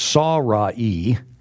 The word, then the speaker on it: Sarai: And now his wife Sarai, as we would say in English, but in Hebrew it's Sarai, the way it's pronounced.